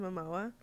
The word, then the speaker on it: Momoa: Momoa, or is that how you say his name? Oh, okay, Jason Momoa.